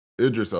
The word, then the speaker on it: Idris: Yeah, I think Ibris, how do you say his name? Idris Elba.